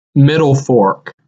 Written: Middle Fork

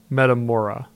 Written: Metamora

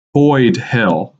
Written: Boyd Hill

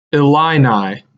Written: Illini

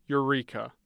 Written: Eureka

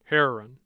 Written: Herrin

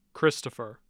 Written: christopher

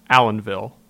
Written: Allenvile

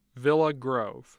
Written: Villa Grove

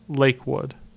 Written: Lake Wood

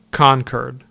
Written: Concord

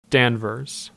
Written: Danvers

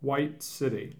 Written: White City

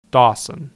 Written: Dawson

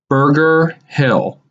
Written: Berger Hill

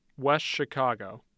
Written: West Chicago

